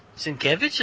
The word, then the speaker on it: Sienkiewicz: Sienkiewicz, is that how you say that? – Yeah, that's how you say it.